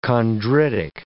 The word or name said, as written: chondritic